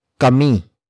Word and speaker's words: kami: The next word is kami, kami, kami. Kami is spelled as K-A-M-I.